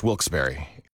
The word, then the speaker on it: Wilkes-Barre: The only way to pronounce Wilkes-Barre.